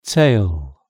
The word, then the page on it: tail: British English